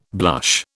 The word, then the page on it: blush: British English